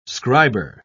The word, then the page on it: scriber: British English